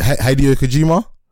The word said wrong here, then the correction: Hideo Kojima: Yeah, Hideo Kojima.